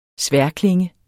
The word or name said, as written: sværdklinge